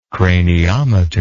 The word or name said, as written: craniometer